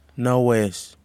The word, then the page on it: nawes: Kwamera